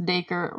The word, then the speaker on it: Dacre: If you ever see his name, it looks like it's hard to pronounce, but it's just Dacre, rhymes with acre.